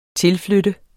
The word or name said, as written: tilflytte